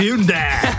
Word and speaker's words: Hyundai: vyladěný Hyundai Tucson. – To se vyslovuje jinak. – Je to tak, je to tak. – Hyundai, jak v té reklamě, Hyundai.